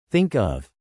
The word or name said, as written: think of